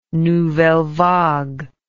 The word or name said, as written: nouvelle vague